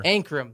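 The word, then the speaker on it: Ankrum: The 54, Tyler Encom, driving for David Gilliland. – Wait, Ancrum or? – Ancrum.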